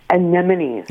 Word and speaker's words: anemone: Most people call them anemones. – Anemones. Okay. – That's the phonetic spelling, right? Anemones. But yeah. – Anemone is the correct pronunciation.